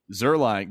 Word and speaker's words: Zuerlein: Yeah, Zuerlein.